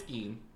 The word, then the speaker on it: Gein: It's Gein.